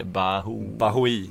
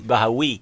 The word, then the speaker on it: Bahoui: Bahoui. – Bahoui. – Om de är kvar. Nej, jag vet inte om de är kvar eller inte. – Bahoui tror jag det ska uttalas för.